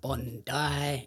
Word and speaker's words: Bondi: And then I actually messaged him and I was like, is it pronounced Bondi or Bondi? Because I didn't want to fucking sound like an idiot. – Yes, it is Bondi. If you say Bondi, Australians are going to look at you like, you're an idiot.